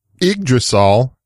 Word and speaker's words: Yggdrasil: Yggdrasil, as it is pronounced, is the world tree in Norse mythology, and they were the first company to create a live CD Linux distro.